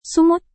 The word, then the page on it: sumut: Indonesian